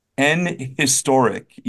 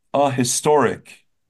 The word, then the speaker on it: historic: I hear it all the time on the news, an historic event. And there's no reason to say an historic if you're saying, if you're pronouncing the H, it'd be a historic, not an historic.